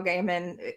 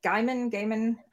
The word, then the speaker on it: Gaiman: And the fact that Neil Gaiman, actually Gaiman, I know I mispronounced Yeah. – that. Thank you, Carl.